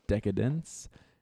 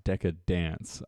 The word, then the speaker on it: Decadence: Decadence. I said it right this time. For those of you who don't know, it's definitely not Decadance. I was saying that for like the last year.